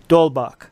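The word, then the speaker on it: d'Holbach: Well, Theo Mackey is saying the H is silent. There's no, it's pronounced Dolbach.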